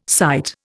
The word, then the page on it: cyte: Indian